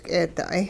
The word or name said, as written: k'eeda'ay